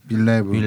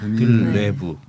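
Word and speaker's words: Villeneuve: 드니 빌레브. 빌레브. – 네, 이젠 좀 외울 때도 되지 않습니까 아, 외웠는데 발음이 안 되네. 뽕네프의 네. 연인들 이런 것처럼 빌레브.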